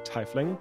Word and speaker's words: tiefling: I don't know if that's an official thing, but the where the word tiefling comes from, which makes it more likely that the pronunciation is actually tiefling.